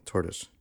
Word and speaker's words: tortoise: Henrik was there saying the word tortoise. That was always a tricky word for me. Do I say it correctly? Tortoise. – Yeah, tortoise.